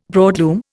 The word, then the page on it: broadloom: Indian